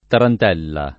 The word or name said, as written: tarantella